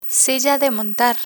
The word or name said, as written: silla de montar